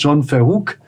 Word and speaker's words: John Verhoek: Zum einen, ich fange jetzt mit dem Gast aus dem Nachbarland an, mit John Verhoek.